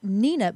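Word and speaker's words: Hnina: That's Nina, but it's spelled with a silent H, so it's H followed by N-I-N-A.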